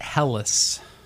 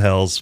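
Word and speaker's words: Helles: So I've always pronounced it Hellas. – We actually went to Germany to do some research before we opened our German-inspired beer hall, and you went to Hell's and back? – Yeah, we went to Hell's and back. It was a trip. And they said Hell's, but... – They did, okay. Yeah. I've been saying it wrong for quite a while.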